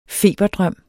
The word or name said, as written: feberdrøm